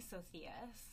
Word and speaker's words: Socias: So, I'm Sydney Socias. My last name is Spanish. No one can pronounce it. – I thought it was Greek. – No, it's Spanish.